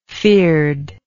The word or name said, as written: feared